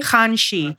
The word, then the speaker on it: Hanshi: Hanshi. – Hanshi. – Wait, Marnina has a very good way of explaining. – It's Han like Hanukkah and she like she's got to have it.